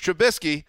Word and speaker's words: Trubisky: And on top of it all, Mitch Trubisky, and not a moment too soon, Wes, is going to be back on the field for the Bears.